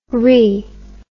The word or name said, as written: riz